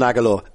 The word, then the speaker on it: Aguilar: Love Nelson Aguilar, and I love the way you nailed his the pronunciation there, like Aguilar. – Aguilar. – We went through four or five different options.